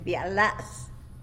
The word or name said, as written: bya las